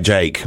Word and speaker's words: JVKE: I don't really understand this marketing, but Jake is J-V-K-E, which kind of makes it, I'm trying to pronounce it correctly. How do you pronounce that? Go on, tell me, how would you pronounce that? J-V-K-E, Jvkka. Well, he goes by Jake.